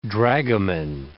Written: dragomen

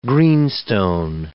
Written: greenstone

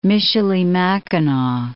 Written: Michilimackinac

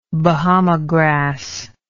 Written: Bahama grass

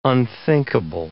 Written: unthinkable

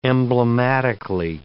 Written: emblematically

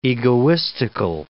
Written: egoistical

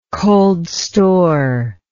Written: cold store

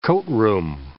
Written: coatroom

